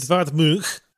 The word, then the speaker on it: Edvard Munch: Yeah, I had to look up a pronunciation for Eduard Munch, a famous painter of The Scream, because his name looks like Edvard Munch. – But it's Edvard Munch.